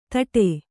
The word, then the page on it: ತಟೆ: taṭe; Kannada